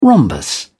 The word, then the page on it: rhombus: British English